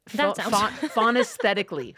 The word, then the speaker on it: phonaesthetically: Is spelled P H O N A E S T H E T I C A L L Y. – Oh. Phonesthetically.